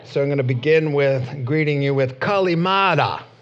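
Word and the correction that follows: Kalimera (Καλημέρα): That is, yeah, I think I pronounced it wrong. Thank you. We actually must have a Greek-speaking person there because it's supposed to mean good morning in Greek. Kalimera, right?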